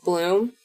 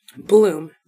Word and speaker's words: Blom: Bloom. – Blom. Bloom. – It's probably pronounced Bloom. – B-L-O-M. There's a slash through the O sideways.